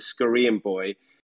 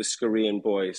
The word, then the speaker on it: Scoreanboy: how do i pronounce my name and, and what does it actually mean the scoreanboy um, and it's actually i'm half scottish half korean uh, and mixed together comes the scoreanboy so that's that's basically where